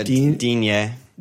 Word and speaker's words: Digne: How do you say his name? – Yeah, Digne.